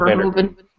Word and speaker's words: Verhoeven: Right, especially because it was a Paul Verhoeven. Am I saying that right? Paul Verhoeven.